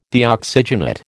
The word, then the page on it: Deoxygenate: American English